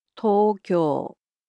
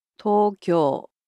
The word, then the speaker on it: Tokyo: The capital of Japan, Tokyo, in Japanese is pronounced as Tokyo. Tokyo. – So it's similar to English, except the O sounds in it are a bit longer.